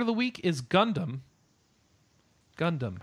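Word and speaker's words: Gundamu (ガンダム): So technically, it's made out for it. Gundam is written Gundam. It's a katakana word, but it's written G A N D A M U. – Gundam. – Gundamu. – Yeah. Oh, GA is more of a gas sound, so that's the reason why. Which is the reason why you will hear it pronounced like that. Gundamu.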